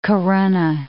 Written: Corunna

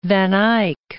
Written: Eyck, van

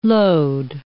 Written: load

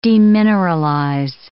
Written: demineralize

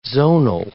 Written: zonal

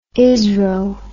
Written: isro

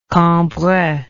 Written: cambrai

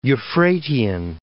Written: Euphratean

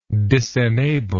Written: disenable

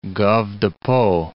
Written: Gave de Pau

